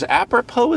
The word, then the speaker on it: apropos: And in other news, apropos is a word with a silent S, I learned.